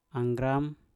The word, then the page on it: anggram: mpur